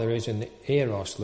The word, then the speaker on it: Eros: I did read that it's correctly pronounced Eros. Eros, whichever.